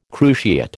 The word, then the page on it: cruciate: American English